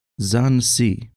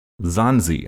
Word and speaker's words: Sahnzee: What did you say they were called? – Sansi. – Sansi, okay. – Yeah, it's spelled Sahnzee.